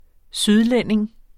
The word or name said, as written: sydlænding